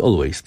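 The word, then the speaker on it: Alvvays: Podobno nie wymawia się tego Always, tylko jednak Always.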